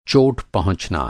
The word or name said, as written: पहुँचना